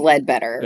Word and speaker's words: Leadbeater: Is it Leadbetter or Leadbeater? – I think it's Leadbetter. – It is Leadbetter. Okay. That's what I wanted to say, but it looks like it's spelled Beater. Leadbetter.